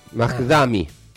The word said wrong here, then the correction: Zoran Mamdani: Lo voy a decir mal, pero porque es difícil de pronunciar. Pero no tengo internet, así que no te puedo comprar. – Es un opositor acérrimo de Donald Trump. Zoran Mamdani.